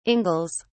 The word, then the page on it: ingles: British English